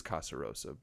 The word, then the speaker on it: Casarosa: Casarosa, gosh, I'm going to mispronounce that so many times. It is Casarosa, but it just, it's a lot of like S's and vowels.